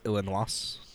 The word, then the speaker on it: Illinois: I believe it's pronounced Illinois. It's French.